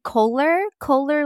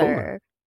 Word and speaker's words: Koller: It's Josephine Kohler like roller. Kohler.